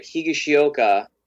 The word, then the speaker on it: Higashioka: When Kyle, I hope I say his name right, Higashioka, I think I said yep. – it right.